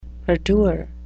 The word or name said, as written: perdure